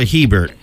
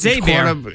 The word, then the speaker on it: Hebert: Is that Abear or Hebert? – I don't know. I know a guy whose last name's Hebert. It's spelled like that. And but I know remember, a quarterback is Abear.